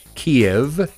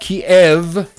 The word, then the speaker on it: Kyiv: Pelosi, second in line to succeed Gropey Joe Biden, is the highest ranking American official to visit Kyiv since the war began. And I'm pronouncing it properly. K-Y-I-V. Kyiv. Not Kyiv. Not Kiev. Kyiv.